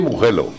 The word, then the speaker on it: Mugello: En italiano se dice Mugello, y ya está.